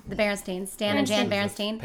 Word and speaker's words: Berenstain: Berenstain. – Berenstain. – Correct pronunciation of that.